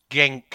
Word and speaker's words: Genk: Well, Genk is apparently how they pronounce it in Belgium, and the other one who sounds like Genk is slightly pronounced differently.